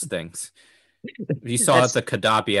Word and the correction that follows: Kabaddi: episode. I can't pronounce anything. Kabaddi. Ha! Didn't mispronounce this time.